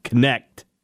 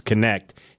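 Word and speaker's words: Knecht: Dalton, I think it's Connect. I think it's spelled pronounced like it's Connect.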